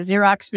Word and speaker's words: Xero: So there's QuickBooks, there's Sage, there's Xero, which is literally like the Xerox machine spelled the same way, but it's pronounced Xero.